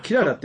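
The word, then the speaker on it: 雲母: ね、キララって面白いですね。母と書いてキララって。 – そうん。ウンモみたいなな、感じで、ね。そう、うん、僕らウニって読むかなと思ったら、ウニではなかった、ね。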